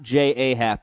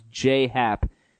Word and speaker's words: J.A. Happ: Not J.A. Happ, by the way, pronounced Jay Happ.